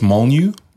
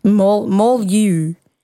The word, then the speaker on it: Molyneux: I think it's Molyneux. – Molyneux. I feel like it's French. – Definitely. The EUX is the giveaway. – So it's the, what, like Molyneux sisters?